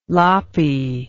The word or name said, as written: loppy